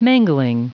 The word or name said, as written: mangling